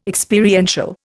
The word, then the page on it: Experiential: Indian